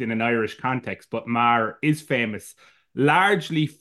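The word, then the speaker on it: Meagher: Can you, as an Irishman, tell us how we're supposed to pronounce Thomas Marr? – Thomas Francis Marr. So as it, you can get a couple of different pronunciations even within Ireland of it. So it's, I wouldn't be too hard on yourselves in relation to pronunciation. But you usually Thomas Francis Marr.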